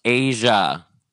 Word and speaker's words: Eja: Well, it's spelt EJA. So like, what would you call that? – Probably Eja? Eja? – Like Eja or like EJ or like oh, EJ. – Whatever. – Right, right. – It's pronounced Asia. Their kid's, their child's name is Asia.